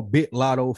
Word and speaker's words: Big Lotto: I'm a Big Lotto fan. You got to say it like you're from Atlanta. Lead a G off. B-I. Hard I. Big Lotto.